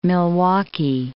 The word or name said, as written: Milwaukee